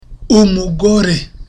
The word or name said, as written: umugore